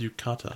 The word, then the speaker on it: yukata: But you see some people in Yutaka. – Is that how it's pronounced? That was Yukata. – Oh, it is Yukata. I bug it up.